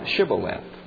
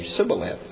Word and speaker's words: Shibboleth: "Say now Shibboleth." And the Ephraimites would say Sibboleth. They could not pronounce it the way that the Gileadites would say it, and that would give them away.